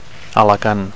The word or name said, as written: Alacant